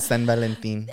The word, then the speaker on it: San Valentín: Oh, yeah, that's how you say it in Spanish. – No. San Juan Bautista. – No, tu San Valentín. – Oh, sí. Yeah, San Valentín.